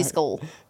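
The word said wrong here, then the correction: sisal: Do you know what that is if I say it correctly? – Sissel.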